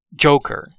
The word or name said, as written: joker